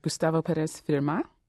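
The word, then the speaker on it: Gustavo Pérez Firmat: Is it Gustavo Perez Firma? – Exactly.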